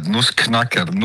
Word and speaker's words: Nussknacker: Bu arada Nussknacker değil mi? Tam doğru telaffuz ediyorum değil mi? – Evet, Nussknacker, Nussbeißer.